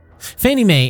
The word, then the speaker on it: Fannie Mae: Fannie Mae is the pronunciation of an acronym, FNMA, which stands for the Federal National Mortgage Association.